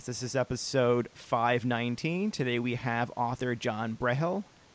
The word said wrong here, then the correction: Burrell: Is that how you pronounce it? John Brehel? – It's actually Burrell. – Burrell. John Burrell.